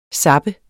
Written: zapper

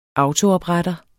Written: autoopretter

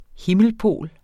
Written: himmelpol

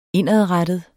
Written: indadrettet

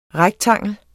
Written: REKTANGLERNES

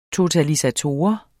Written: totalisator

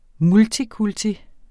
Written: multikulti

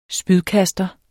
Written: spydkaster

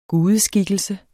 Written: gudeskikkelse

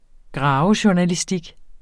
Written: graverjournalistik

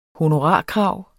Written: honorarkrav

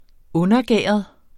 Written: undergæret

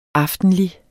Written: aftenlig

